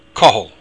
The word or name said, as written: cochaill